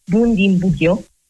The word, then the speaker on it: Bundibugyo: ma è Bundibugyo,